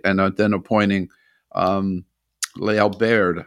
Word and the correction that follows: Lael Brainard: I think that's how you pronounce her name, as the Lael Brainard, yes. – Brainard, right, as the number two.